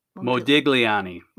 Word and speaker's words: Modigliani: Mogulani. – I know who that is, but that's not how you say it. – And his wife. Modigliani.